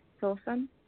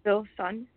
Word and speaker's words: Filsan: Silson. – Spell that for us. – S-I-L. – Yeah. Yeah. I remember you. F-I-L-S-U-N. S-U-N? – S-A-N, but you say it like... – Filson. Yeah. Okay, I'm sorry. F-I-L-S-A-N.